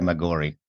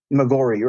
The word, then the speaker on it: Maggiore: Maggiore, Maggiore, I think it is. Yeah, they pronounce it. Yeah, Santa Maria. – What's that? – Santa Maria Maggiore. – Maggiore, right.